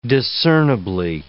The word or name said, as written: discernibly